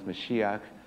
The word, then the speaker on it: Mashiach: The Hebrew for anointed one is Mashiach. We pronounce it Messiah.